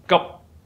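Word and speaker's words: gup (急): The second character is ji, urgent, in Mandarin. But when it's pronounced in Cantonese or Middle Chinese, it's pronounced as gup. In order to pronounce it correctly, you must close your mouth.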